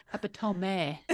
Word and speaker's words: epitome: I think it's actually pronounced epitome. So, I have been pronouncing it wrong this whole time.